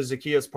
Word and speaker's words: Zaccheaus: They're not happy with Russell Gage and Olamide Zaccheaus. I hope I'm saying that right. – You got the Zaccheaus part right, and that's all we care about.